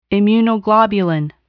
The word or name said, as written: immunoglobulin